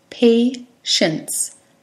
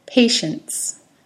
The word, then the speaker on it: patience: Patience. It was just two syllables. Patience. Patience. The emphasis is on the first syllable, pay.